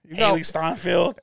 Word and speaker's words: Hailee Steinfeld: By Hailee Steinfeld. – It's good. – I hope I'm saying the name right. – Yeah, it's Hailee Steinfeld.